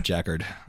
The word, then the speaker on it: Jacquard: Jack Hard. – Is that your name? – Jackard. – I just kept saying Jack Hard. – Well, it's pronounced like Jacquard.